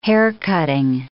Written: haircutting

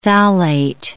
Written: phthalate

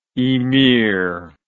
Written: emeer

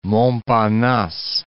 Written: Montparnasse

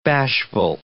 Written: bashful

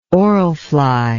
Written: orl fly

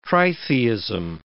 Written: tritheism

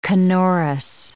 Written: canorous

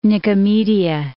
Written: Nicomedia

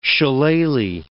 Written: shillelagh

